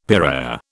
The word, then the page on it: piraya: British English